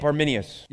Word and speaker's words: Arminius: So let's look at the Calvinism of Jacob Arminius.